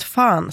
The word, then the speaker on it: fan: Men så har vi ordet fan, som ju de allra flesta uttalar med ett långt A-ljud motsvarande A-ljudet i Milano. Med italienskt uttal, skriver han här.